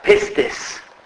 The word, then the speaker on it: pistis: So the pronunciation, as far as I'm concerned, is this. It's the word pistis. P-I-S-T-I-S.